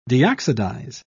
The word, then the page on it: deoxidize: British English